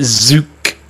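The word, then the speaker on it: Tzuke: Judy Zook, T-Z-U-K-E. I pronounce it Zook. You can pronounce that how you want to, but you know what?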